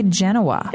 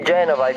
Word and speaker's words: Genova: This has been a big question because, you know, in English we say Genoa. – If you can pronounce the V, you can say Genova. If you cannot, just say Genoa.